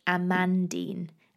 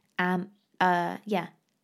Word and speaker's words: Amandine: The only thing that annoys me with Amandine, of course, is that it's quite difficult to pronounce because it's spelt A-M-A-N-D-I-N-E. So we find in the UK, especially, a lot of people say Amandine. Amandine. So literally saying it as it's spelt, but it's not. You've got to imagine that the second A is an O. That's the closest you'll get to the French saying. It's Amandine. I mean, even I don't say it that well.